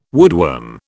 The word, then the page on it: woodworm: British English